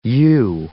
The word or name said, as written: yew